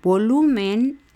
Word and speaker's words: volumen: Volumen, volumen son tres sílabas, la última es men, la penúltima es lu, pero no lleva acento, aunque sí lleva acento prosódico, o sea, sí se va a pronunciar el acento. A nosotros decir volumen, se está acentuando en lu, que es la penúltima sílaba, pero no lleva el tilde porque termina en N.